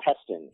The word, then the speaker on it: Heston: Rhymes with Weston. – Heston. – Yes, Heston.